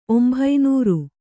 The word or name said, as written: ಒಂಭೈನೂರು